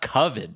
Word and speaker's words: Coven: And he's like, it's pronounced Coven, dude. It's Coven, dude.